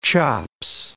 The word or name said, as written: chops